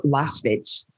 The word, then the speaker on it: Lasvit: Last fit. So it's a V in it, L-A-S-V-I-T.